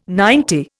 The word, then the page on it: ninety: Indian